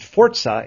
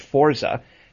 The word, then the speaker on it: Forza: You got to do that extra bit of work to get the t in there if you do Forza. – I just, you know, it's like Forza. Like, you got to say it like a really spirited Italian guy who's really into the races, you know? – Or you sound like Mario, you know?